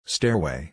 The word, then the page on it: stairway: er